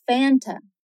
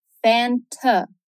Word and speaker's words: Fanta: A pronúncia americana seria Fanta. Fanta. Essa aqui é um pouquinho relativa, porque tem lugares diferentes com pronúncias diferentes. Alguns lugares soa mais como um Fanta e outros mais como um A bem fechado, um Fanta.